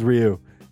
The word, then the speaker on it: Ryu: Say it in one syllable. – Ryu. – No, one syllable. – Ryu. – That's two. – One syllable. – Ryu.